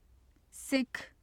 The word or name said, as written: sikh